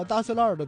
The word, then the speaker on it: 大栅栏: 其实说到大石栏儿的读音，就是本地人都叫大石栏儿，然后其实它就根本就不是字面上那个大栅栏，就是这也是老北京话的一个魅力的体现的地方，就是一是儿化音，然后二是方言的一种演变。